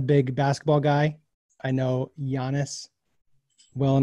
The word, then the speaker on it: Giannis: Last topic of the day, Giannis Antetokounmpo. Did I say that right? – Giannis.